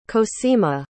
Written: cosima

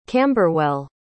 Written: camberwell

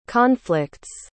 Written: conflicts

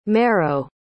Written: merrow

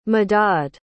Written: madadh

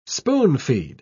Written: spoon-feed

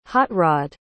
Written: hot-rod

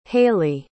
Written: haylee

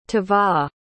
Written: tovah

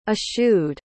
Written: eschewed

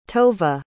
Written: tova

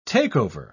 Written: take-over

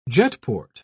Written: jetport

